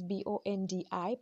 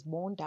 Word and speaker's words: Bondi: I love the fact that it's spelled as Bondi, but it's pronounced as Bondi. I would read it as Bondi.